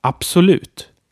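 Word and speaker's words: absolut: And another one is absolute. Absolute. So all words that begin with ABS are spoken this way, like abs, absolute, absorbera, absolvera, etc.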